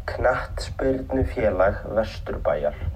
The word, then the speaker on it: Knattspyrnufélag Vesturbæjar: and I'll tell you how to pronounce it right now. It's Knattspyrnufélag Vesturbæjar. So there you go.